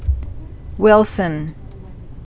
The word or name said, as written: Wilson's disease